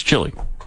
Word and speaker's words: Chile: I'm not going to factor in Chile for people who pronounce it that way. It's Chile. Okay? It's like the restaurant. They've named a country after the restaurant.